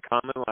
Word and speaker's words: Conlon: So my name's, you know, Henry Conlon. Last name's spelled C-O-N-L-O-N.